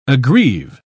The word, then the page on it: aggrieve: British English